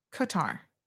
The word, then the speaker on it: Qatar: Oh, Soka says it's pronounced almost like cutter. So Qatar, like that? Qatar.